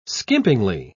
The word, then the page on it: skimpingly: British English